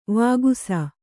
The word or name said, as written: ವಿಕಾಸ